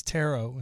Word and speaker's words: tarot: So it's tarot instead of tarot. I don't know why I was saying tarot, like parrot. I guess because it's spelt that way, right? So I just pronounced it the way it looks like it's spelled.